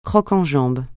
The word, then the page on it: croc-en-jambe: francais